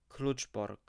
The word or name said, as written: Kluczbork